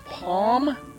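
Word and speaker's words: Pomme: Artist whose name I will pronounce Palm. That's P-O-M-M-E.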